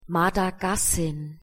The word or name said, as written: Madagassin